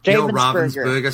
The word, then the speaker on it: Ravensburger: Am I saying that right? – Ravensburger, same thing. Depends if you're German or not.